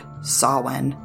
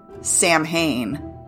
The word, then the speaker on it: Samhain: I'll be one of those obnoxious people that actually pronounce it Sam Hane, or I'll just say Halloween.